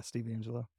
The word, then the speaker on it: Steve Angello: But it's funny using it in this like today they posted um fucking uh who's Steve Angello? Is that his name from Swedish House Mafia? Swedish House. How did I say that? Like uh, Swedish House Mafia. Um Yeah, Steve Angello.